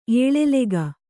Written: ಎಲೆಲೆಗ

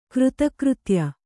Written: ಕೃತಕೃತ್ಯ

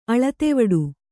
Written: ಅಳತೆವಡು

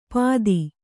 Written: ಪಾದಿ

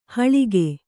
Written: ಹಳಗೆ

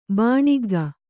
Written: ಬಾಣಿಗ್ಗ

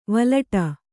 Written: ವಲಟ

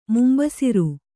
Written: ಮುಂಬಸಿರು

